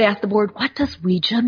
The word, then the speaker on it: Ouija: they believed that was Ouida in the locket, but Ouija is what came out, and it's actually pronounced Ouija. That's how they said to pronounce it in the original patent.